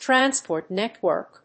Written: transport network

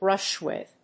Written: brush with

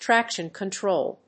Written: traction control

